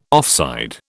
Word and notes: offside: British English

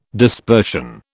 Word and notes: dispersion: British English

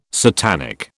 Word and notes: satanic: British English

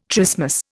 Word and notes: trismus: Indian

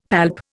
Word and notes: palp: Indian

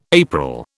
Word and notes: April: British English

